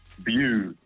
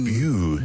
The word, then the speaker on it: Bew: Bew. It's like blue without the L. – Oh, Justin Bew. – Bew.